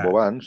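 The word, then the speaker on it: Vauban: Sí, però que, Bauban, sí.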